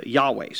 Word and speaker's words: Yahweh: So, uh, what is now pronounced uh, Yahweh.